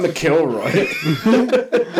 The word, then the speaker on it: McIlroy: No, no, you had it right the first time. McIlroy. Don't listen to him. It's not McIlroy.